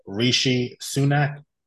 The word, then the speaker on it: Rishi Sunak: There was Theresa May, Boris Johnson, Liz Truss, and recently now you have, and I hope I'm pronouncing this right, Rishi Sunak. Is that right? – Yeah.